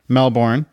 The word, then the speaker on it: Melbourne: As M-E-L-B-O-U-R-N-E. So Jason Bourne. Melbourne, right? This is how we communicate. This is how we pronounce. And it's Melbourne. M-E-L-B-I-N is how he's pronounced.